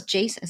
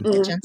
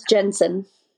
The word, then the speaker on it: Jensen: I thought it was Jason. Is it Jason or Jensen? – Jensen. – It's Jensen.